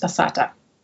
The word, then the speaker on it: passata: You mentioned that you always recommend making this with a homemade tomato sauce, which I think you call a passata, if I pronounce that correctly. – So we recommend a homemade tomato sauce. And if you don't have one, then to use passata.